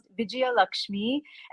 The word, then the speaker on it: Vijayalakshmi: My name is pronounced Vijayalakshmi, and it means goddess of victory.